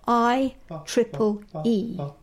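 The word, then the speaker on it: IEEE: The Harvard sentences are a set of 720 sentences developed by the Institute of Electrical and Electronics Engineers, the IEE, pronounced I-triple-E.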